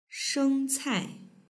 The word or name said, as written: shengcai